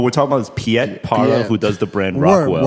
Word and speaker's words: Parra: This is Parra. – Parra. – That's how we pronounce it. Piet Parra.